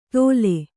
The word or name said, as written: ತೋಲೆ